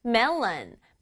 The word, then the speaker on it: melon: Melon, melon. 메론은 영어로 발음할 때는 melon 이렇게 발음해줘요.